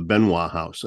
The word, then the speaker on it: Benoist: a historic house called the Benoit House, and it's spelled Benoist.